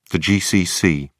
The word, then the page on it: gcc: British English